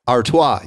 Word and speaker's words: Artois: Artois.